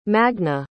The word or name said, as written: magne